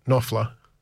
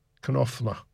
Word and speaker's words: Knopfler: Sung by then Mark Knopfler, pronounced Knopfler. Mark Knopfler. Knopfler. Anyway, it's a German. The German word Knopf, K-N-O-P-F, means button.